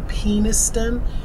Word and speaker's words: Peniston: The name is C.C. Peniston. Emphasis on the penis. – Peniston?